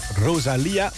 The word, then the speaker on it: Rosalía: En deze Rosalía, want zo moet je het uitspreken, die heeft nummer met haar opgenomen.